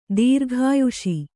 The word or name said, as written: ದೀರ್ಘಾಯುಸ್ಸು